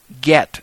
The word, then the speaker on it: get: Divorce in Judaism today is accomplished by the Bet Din through a document known in Hebrew as a get, and it's spelled G-E-T, just like get me a cup of water, get me a cup of tea, or get something.